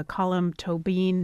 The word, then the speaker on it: Colm Tóibín: This is the adaptation of the Colm Tóibín novel. That is how you pronounce it, by the way. I looked it up.